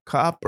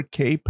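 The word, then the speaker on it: Kape: He should fight Manal Cop or Cape, however you say his name. I think it's Cop.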